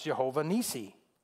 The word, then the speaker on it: Jehovah Nissi: In a Hebrew word, it's pronounced Jehovah Nissi.